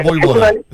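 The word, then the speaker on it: Vojvoda: Se pronuncia de esta manera. Que claro que sea Boivoda.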